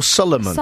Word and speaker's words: Suleiman: Yeah, Solomon or Solomon? – Solomon. – Spell it for us. – S-U-L-E-I-M-A-N.